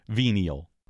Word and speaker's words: venia: Venial. – Well, it comes from the Latin venia. The V would be pronounced w in which is forgiveness, indulgence, pardon, or favor.